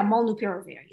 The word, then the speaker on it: molnupiravir: Ah, that's how you say it. – Well, yeah, molnupiravir, yeah. It took me a while.